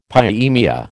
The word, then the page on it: pyemia: American English